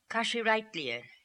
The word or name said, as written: Kashireitleer